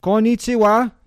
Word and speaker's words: Konnichiwa: Konnichiwa, is that how you say it? – Oh yes, for daytime, yes.